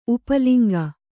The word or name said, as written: ಉಪಲಿಂಗ